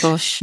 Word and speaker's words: Ghosh: Ghosh.